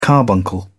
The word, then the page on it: carbuncle: British English